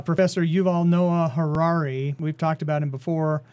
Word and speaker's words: Yuval Noah Harari: Yuval Noah Harari.